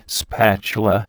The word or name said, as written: spatula